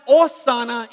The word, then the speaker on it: Hosanna: It's pronounced Hosanna in the Greek, in the text, literally, which means save now.